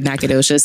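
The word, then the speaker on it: Nacogdoches: Which if you don't know how to spell it, like me saying that there's a G in the word Nagadoches sounds weird. – Yeah. – It's weird. – But I'm telling you, looking at the letters phonetically, it does not say Nagadoches.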